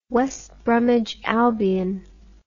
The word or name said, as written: west bromwich albion